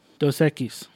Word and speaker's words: Dos Equis: What do you got? Dosakis? – Dos Equis. – Did I say it wrong? – Not, you said Dos Equis. – Dosakis. – That's it. – No. – Dos Equis. – No, this is the most interesting man in the world's brew.